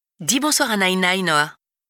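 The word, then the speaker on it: Nainai (奶奶): Il a d'ailleurs insisté pour que Noah m'appelle grand-mère en chinois, Nainai, qui se prononce Nainai.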